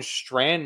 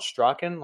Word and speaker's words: Strachan: so it's pronounced Owen Strand, not Strachan, like Strand, that is Welsh, maybe, I don't know.